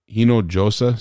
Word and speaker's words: Hinojosa: Uh, Gilberto Hinoja. Hino Josa, sorry I butchered your name.